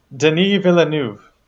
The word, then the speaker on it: Denis Villeneuve: And I practiced all day yesterday, so I hope I can pronounce this correctly, is 49-year-old French-Canadian filmmaker Denis Villeneuve.